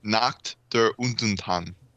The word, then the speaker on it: Nacht der Untoten: Topping off the list is Nacht der Untoten. Did I pronounce that right? Does anybody German? – Do you want me to say it for you? – Yes. – Nacht der Untoten.